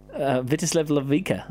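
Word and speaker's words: Vítězslav Lavička: Vitislav Lavica. – Yeah, is that right? – Correct. – Is that pronounced correctly? – That is pronounced correctly.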